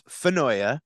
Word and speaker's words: Finoia: It's Mike Finoia. – Almost. – Almost. – 98%. Give you an A minus, but yeah, great effort. It's Finoia.